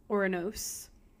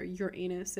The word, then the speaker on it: Uranus: Uranos, actually, is the proper way to say that. Or Uranus, if you're a 90s kid and you think it's hilarious.